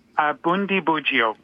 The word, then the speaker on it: Bundibugyo: Bundibugyo.